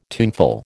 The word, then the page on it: tuneful: American English